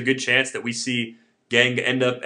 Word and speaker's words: Kang: They signed a shortstop from Korea, Jung-ho Kang, though I think I've been told it's pronounced Gang instead of Kang.